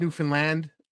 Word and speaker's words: Newfoundland: And it's pronounced Newfoundland.